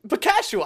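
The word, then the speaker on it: Buchnevich: Bakash Hihua. – You got close. Bakashua.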